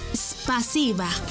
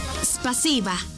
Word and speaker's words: спасибо: En ruso, gracias se dice спасибо. ¿Cómo? Спасибо. Una vez más. Спасибо. Y se escribe спасибо. S-P-A-S-I-B-A. Спасибо.